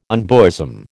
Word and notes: unbosom: American English

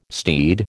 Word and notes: steed: American English

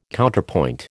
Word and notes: Counterpoint: American English

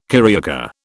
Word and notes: carioca: British English

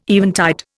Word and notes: eventide: Indian

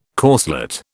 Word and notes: corselet: British English

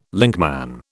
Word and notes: linkman: British English

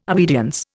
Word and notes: obedience: Indian

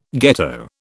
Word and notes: ghetto: British English

